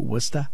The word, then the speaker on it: Worcester: Or if you want to say it like the natives do, Worcester?